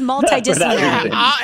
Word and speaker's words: multidisciplinary: Here to share more is Betty Aldworth and Stephen Huang from MAPS, the Multidisciplinary Association of Psychedelic Studies, or multiple disciplinary. I always say that, but now I guess I've been saying it wrong the whole time. It is multidisciplinary.